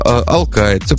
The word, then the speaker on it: алкает: а я пока вот ударение в слове «алкает» ищу, да, все же правильно произнес, интересно же, да, «алкает», все правильно.